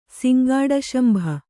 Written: ಸಿಂಗಾಡಶಂಭ

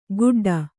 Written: ಗುಡ್ಡ

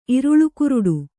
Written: ಇರುಳುಕುರುಡು

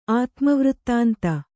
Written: ಆತ್ಮವೃತ್ತಾಂತ

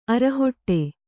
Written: ಅರೆಹೊಡೆ

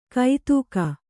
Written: ಕೈತೂಕ

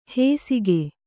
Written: ಹೇಸಿಗೆ

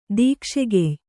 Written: ದೀಕ್ಷೆಗೆಯ್

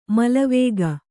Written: ಮಲವೇಗ

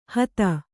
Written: ಹತ

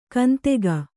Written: ಕಂತೆಗ

